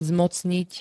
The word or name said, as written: zmocniť